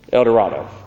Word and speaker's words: El Dorado: El Dorado? How do you say that? El Dorado.